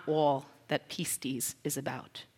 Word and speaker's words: pistis: That's what how we pronounce that, pistis.